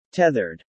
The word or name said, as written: tethered